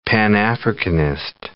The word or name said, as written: Pan-Africanist